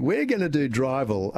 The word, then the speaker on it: Drivl: So we came up with the word Drivel, which is D-R-I-V-L, which could be interpreted in many different ways.